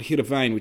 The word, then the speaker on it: Heerenveen: I remember this one time when I lived in Holland, what we would do, I lived in this town called Heerenveen, which is spelled H-E-E-R-E-N-V-E-E-N.